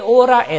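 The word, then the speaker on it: hora: 그래서 꾸에 오라 에스 몇입니까? 그래서 오라라는 말을 쓰는데 스페인은 본래 H음이 묵음이 되죠. 그래서 호라가 아니라 오라가 되는 겁니다.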